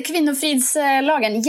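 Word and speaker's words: Kvinnofridslagen: I know Sweden has, you're going to have to correct me on the pronunciation on this, but they have the Kvinnofrid law? – Oh, the Kvinnofrids lagen, yes.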